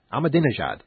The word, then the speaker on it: Ahmadinejad: And then you have different areas of the world sort of combining forces, Chavez and Iran's Mahmoud Ahmadinejad. You know, I was saying that right a couple of weeks ago. Let me get it right again. Ahmadinejad, yes, the Iranian leader.